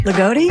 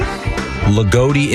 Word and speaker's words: Loogootee: L-O-O-G-O-O-T-E-E. – Lagodi? – Lagodi, Indiana. – Lagodi, Indiana. – Lagodi, yeah. – Sure. – Wow, Lagodi. That's a great Lagodi name.